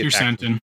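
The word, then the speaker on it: Krrsantan: The Wookiee Kersantan. – Kersantan? Fett, Shand, and the guards and the cyborgs fight off the Wookiee and then capture him. The twins apologize for sending Kersantan that the mayor, Chase, had promised Jabba's territory to another syndicate.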